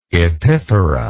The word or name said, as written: epiphora